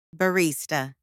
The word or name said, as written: barista